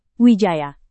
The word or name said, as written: wijaya